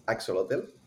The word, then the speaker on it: Ak-Xolotl: It's a really difficult name to pronounce, but it's Ak-Xolotl. And it was an arcade shooter before, very arcadey, and now it's become a full-on roguelite. – Oh, nice. So, yeah, and you play as a hungry Ak-Xolotl in search for food or violence, maybe both.